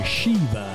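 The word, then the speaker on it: Siobhan: si scrive in tutt'altro modo, ma abbiamo appurato che la pronuncia di questa splendida voce, pronuncia del suo nome si intende, è Shiva. La trovate però con la scritta S-I-O-N-H-A.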